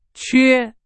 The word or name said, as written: 缺